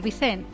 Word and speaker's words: Vicent: Vicent es de Castelló, lo he pronunciado sin la N, no me sale pronunciarlo con ella. Tal vez porque aprendí de niña esa palabra en valenciano y de adulta tengo que pensarla en castellano.